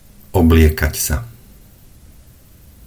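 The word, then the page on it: obliekat sa: Slovak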